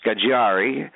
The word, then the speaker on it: Scaggiari: My first name is spelled S-T-E-F. That's short for Stefan or Stefano. And my last name is Scagiari. It's an Italian name, and it's spelled S-C-A-G-G-I. A-R-I.